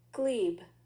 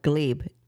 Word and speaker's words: glebe: Glebe. – Dweeb? – No, glebe. – Oh, I thought you said dweeb. – No, like, glebe with a G. – Glebe? Spell it. – G-L-E-B-E.